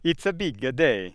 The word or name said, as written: Italian